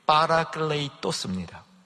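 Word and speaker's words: 파라클레이토스입니다 (παράκλητος): 자, 발음을 좀 들어보세요. 보혜사는 파라클레이토스입니다. 위로라는 말은 파라클레이시스예요. 뒤에 토스와 시스 차이입니다.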